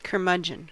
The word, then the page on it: curmudgeon: American English